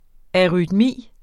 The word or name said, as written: arytmi